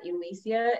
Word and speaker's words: Elysia: And then you can find me on Facebook at Elisa, and that's spelled Elysia, and then Jane, Jane, and then last name Bus, Buss.